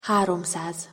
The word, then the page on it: haromszaz: Hungarian